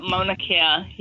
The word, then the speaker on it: Mauna Kea: And that's the telescope at, is Mauna Kea? Is that how you say it? – Mauna Kea. Yep, Mauna Kea.